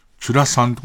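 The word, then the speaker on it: ちゅらさん: チュラさん。俺がまあ、チュラさん見てるって言ったら、チュラさんはチュではなく、ラさんにアクセントを置いて読むのが正しい。チュラさんってこと最後に注意されるやつだろうな、俺。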